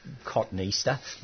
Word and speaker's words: Cotoneaster: Yes, you regularly hear it pronounced cotton Easter. it is spelt that way.